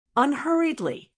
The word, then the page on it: unhurriedly: American English